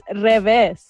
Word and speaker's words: revés: Ew, no, you're not saying it right. It's revés. – You revés? What's it supposed to be? What? – Revest. – Re- Revest, but roll your R at the beginning.